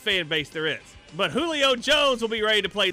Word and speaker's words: Julio: Some guy takes Julio Jones first round, and some people snicker, and then somebody leans over to him and says, dude, the J is silent in Julio. And the guy that took him goes, oh, okay, Julio Jones.